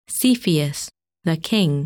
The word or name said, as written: Cepheus